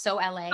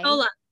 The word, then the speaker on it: Sola: so is it pronounced So LA? – Sola.